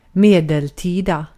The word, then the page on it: medeltida: /ˈmeː.dɛlˌtiː.da/